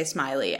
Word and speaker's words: Smellie: So his name is actually pronounced Smellie. So excuse me if I mispronounce it several times during this episode because I'm reading it and it looks like Smellie. So I'm going to try my best to say Smiley every time, but who knows? – I don't know what's funnier.